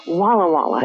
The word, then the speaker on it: Walla Walla: But you and I don't say it right because they say it Walla Walla.